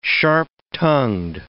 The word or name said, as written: sharp-tongued